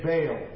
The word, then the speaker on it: Baal: In English, we say Baal. B-A-A-L. Baal. A Canaanite god. It should be pronounced Baal.